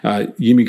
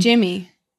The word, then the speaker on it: Yimi: So I said Yimi. I think Stoughton called it out that Atkins was calling him Yimi, and then the pronunciation guide has changed or something. – It's Jimmy. – But I think it's gone back is what I'm saying. That everyone was calling him Jimmy, but then Atkins was calling him Yimi, and then the pronunciation guide said it was Yimi.